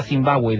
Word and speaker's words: Zimbabue: La llamarían casas de piedra, que expresado en la lengua local se pronuncia Zimbabue, de ahí el nombre actual del país.